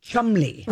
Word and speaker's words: Cholmondeley: You look at it, it looks like Cholmondeley. The way it's spelled, Cholmondeley. But it's pronounced Cholmondeley.